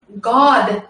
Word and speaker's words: Gad: They think, see, Christians and Hebrew roots think that the Jews, all the Jews, are calling on God, G-O-D. They're not. They're calling on Gad, but it's pronounced God. But it's G-A-D.